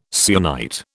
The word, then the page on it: syenite: British English